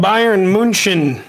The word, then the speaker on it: Bayern München: Well, Denny, their best team is called Bayern München. That's how you pronounce it.